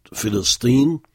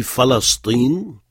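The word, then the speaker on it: Philistine (فلسطين): Normalement, on doit dire Philistine, mais on entend aussi Falastine ou Philastine. Mais il vaut mieux retenir Philistine.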